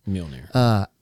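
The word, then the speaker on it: Mjölnir: When he wielded, how do you say it? Mjolnir? – Mjolnir.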